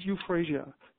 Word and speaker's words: euphrasia: The homeopathic medicine for the eyes, for irritated red eyes, is euphrasia. You can use eye drops, and usually homeopathic eye drops will contain euphrasia, but you can use also euphrasia 6C in pellets if you have, inflamed eyes, provided, of course, it's not too infected.